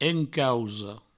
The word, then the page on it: encausa: encauso